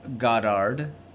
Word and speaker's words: Goddard: Is it Goddard? – I'll just stop. – Drew. – His name is Drew Goddard. G-O-D-D-A-R-D for some reason.